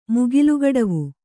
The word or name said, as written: ಮುಗಿಲ್ಗಡವು